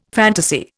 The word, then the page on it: fantasy: Indian